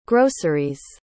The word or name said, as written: groceries